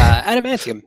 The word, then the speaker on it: adamantium: Adamantium.